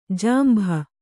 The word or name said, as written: ಜಂಬು